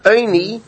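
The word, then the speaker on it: Eini (עני): In fact, even though it's pronounced Eini, but if you look, it's spelled Ayin Nun Yud, which is ani, right?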